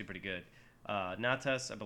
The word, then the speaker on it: Natas: Your second character goes by the name Natas. – Natas. Can you spell that for me? – Sure. N-A-T-A-S.